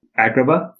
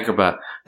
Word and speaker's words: Agrabah: Like the Agabara. Is that how you say it? What's the Aladdin thing? – Agrabah? – Yeah, Agrabah.